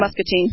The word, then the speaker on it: Muscatine: If you ever visit, how do you say, Muscatine or Muscatine? – Yes, Muscatine.